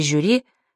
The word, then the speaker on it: жюри: «Парашют» и «брошюра» произносятся твердо, а в слове «жюри» шипящий звук мягкий.